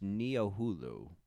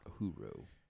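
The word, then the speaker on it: Niohuru: It's pronounced Neo-Hulu, not Huru.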